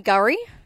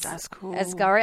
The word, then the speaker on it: K'gari: So it is the name Gurri is spelt K'Gari. So Gurri means paradise in the native Butchala language. And it's also now going to appear on official maps and signs as Gurri.